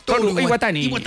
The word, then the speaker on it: Toru Iwatani: Toru Iwatani. ¿Cómo se pronunciaría eso en japonés? – Toru Iwatani.